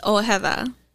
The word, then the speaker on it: Ojeda: Even I butcher it, but it's Ojeda. – Yeah. – O-J-E-D-A.